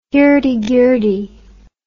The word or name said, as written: hirdie girdie